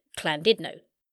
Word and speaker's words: Llandudno: where I quickly found out the Welsh pronounce it Llandudno.